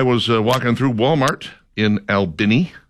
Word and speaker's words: Albany: This is a weird story out of Albany, Georgia. That is the correct pronunciation, in case you're wondering.